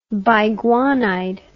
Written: biguanide

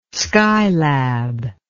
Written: skylab

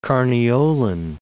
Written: Carniolan

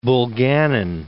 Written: Bulganin

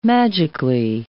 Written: magically

